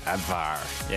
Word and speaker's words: Abwehr: W is pronounced like a V. Abwehr.